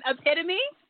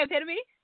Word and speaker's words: epitome: Epitome? Are you trying to say epitome? – Yeah, you're right. Epitome, my bad.